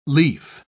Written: lief

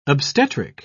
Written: obstetric

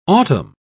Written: autumn

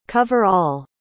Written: cover-all

